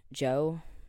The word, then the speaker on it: Djo: That new, the Joe song, the DJO, but, like, it's pronounced Joe Kiri song, that's really popular right now.